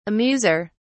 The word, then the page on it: amuser: British English